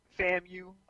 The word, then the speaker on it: FAMU: Wait, I know the initials, but I never was clear how to pronounce it, though. – FAMU, Florida Agricultural and Mechanical University.